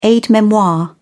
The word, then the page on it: aide-memoire: British English